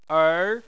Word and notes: er: 2

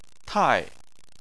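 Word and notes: tai: tone 4

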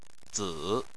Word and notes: zi: 3

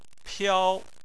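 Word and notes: piao: tone 1

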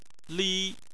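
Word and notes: li: tone 1

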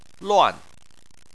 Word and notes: luan: 4

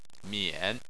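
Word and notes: mian3: tone 3

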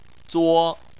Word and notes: zuo: 1

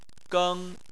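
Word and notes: geng: tone 1

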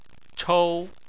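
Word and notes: chou: tone 1

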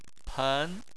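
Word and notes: pen: tone 2

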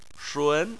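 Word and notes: shun2: tone 2

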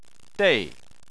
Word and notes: dei: 4

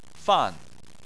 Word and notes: fan: 4

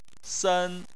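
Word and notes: sen: tone 1

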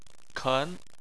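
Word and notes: ken: tone 2

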